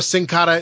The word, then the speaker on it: Sin Cara: Uh, or Sin Cara is down in Mexico. Am I close? – Oh, this is perfect pronunciation.